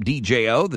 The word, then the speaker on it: Djo: That is Joe, his musical stage name, DJO. The D is silent.